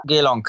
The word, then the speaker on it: Geelong: Also Ashrun ist jetzt gelaufen am Mittwoch, ist Vierter geworden im, wie wird das ausgesprochen, Geelong Cup? – Geelong Cup, genau.